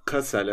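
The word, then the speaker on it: Kassel: Yaşadıkları yerin valili, valiliği Kasel diye mi okunuyor? – Kasel evet.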